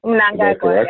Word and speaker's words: Mnangagwa: Is it Mnangagwa? – Mnangagwa, yeah.